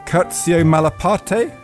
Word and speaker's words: Curzio Malaparte: Ian Baruma has written about the Italian fascist and fabulist Curzio Malaparte.